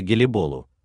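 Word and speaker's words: Gelibolu: Например, Галлиполи по-турецки произносится Гелиболу, а Чинак более точно пишется как Шанак.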